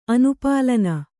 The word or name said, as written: ಅನುಪಾಲನ